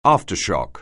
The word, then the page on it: aftershock: anglais